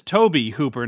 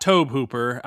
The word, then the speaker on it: Tobe Hooper: And that is Tobe Hooper, not Tobe Hooper. I've seen a whole lot of influencers mispronounce that dude's name recently.